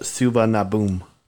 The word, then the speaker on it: Suvarnabhumi: This next one, Chris, is pronounced Suvarnabhumi. – Suvarnabhumi Airport, Bangkok, Thailand.